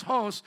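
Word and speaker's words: hos (ὅς): And hos, even though it's O-S, has a breath mark there, so it's pronounced hos as he or which, in that way, which he appeared, or is it theos, God appeared?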